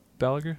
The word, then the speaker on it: Balaguer: Balaguer? – Yeah.